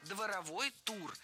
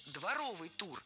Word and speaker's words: дворовый: Дворовой тур. На самом деле должен быть дворовый тур. В прилагательном, образованном от существительного «двор», ударение падает на суффикс «ов», а окончание должно быть «ый», а не «ой». Слово «дворовой» есть в русском языке, но это не прилагательное, а существительное.